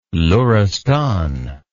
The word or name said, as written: luristan